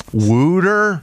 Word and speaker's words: wooder: Wooder? – Yeah. – Seriously. I know. They got it. – Where producers got to get in somebody's ear and say that's how they pronounce W-A-T-E-R in Philadelphia.